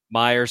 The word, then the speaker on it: Meijer: He left an area Meijer store. Did I say that right? – Meijer store.